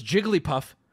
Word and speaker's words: Jigglypuff: Because Jigglypuff is a combination of two words that when combined have three syllables.